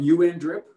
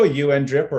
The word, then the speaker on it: UNDRIP: So you think that this, say it again, UN drip, how do you pronounce this? – You can go UN drip or UNDRIP. Or UniDrip. UniDrip's great.